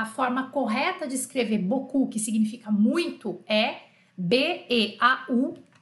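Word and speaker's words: beau: é bizarro que boku se escreve e -A B E A U, quer dizer, o O é E A U e o U é O U.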